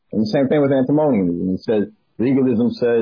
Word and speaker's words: antinomianism: Antinomianism. – Antinomianism, yeah.